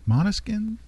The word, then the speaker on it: Måneskin: Måneskin? – Yeah.